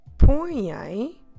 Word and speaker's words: põem: So if I want to say, for instance, they laid the table or they set the table, I say eles põem a mesa. Eles põem a mesa. Eles põem a mesa. That's right, we try to put põem together. Eles põem a mesa because it's all uh, vowel sounds.